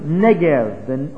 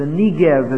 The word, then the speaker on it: Negev: This is the area that's called the Negev, or the Negev as it's sometimes pronounced, even in our day in Israel.